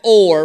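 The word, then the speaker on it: Hor: Verse 4 says this, from Mount Or. We're going to go with a silent H there, okay?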